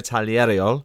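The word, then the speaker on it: Tagliariol: It's Matteo Taglieriol. I had to practice that name before I spoke to Matteo, but he assures me that's how you say it.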